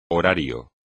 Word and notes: horario: espagnol

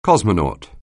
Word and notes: cosmonaut: anglais

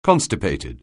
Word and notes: constipated: anglais

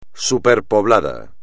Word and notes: superpoblado: espagnol

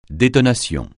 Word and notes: détonation: francais

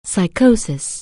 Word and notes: psychosis: anglais

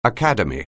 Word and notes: academy: anglais

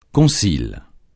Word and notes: concile: francais

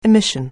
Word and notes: emission: anglais

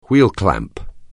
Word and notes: wheel clamp: anglais

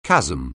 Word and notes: chasm: anglais

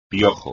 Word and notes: piojo: espagnol

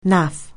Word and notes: naff: anglais

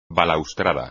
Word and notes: balaustrada: espagnol

